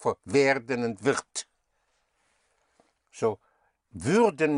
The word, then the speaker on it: würden: You have würden. Werden is will. Würden. Spelled W-U-Umlaut-R-D-E-N. Instead of werden, if you make it würden, you give it an Ü sound.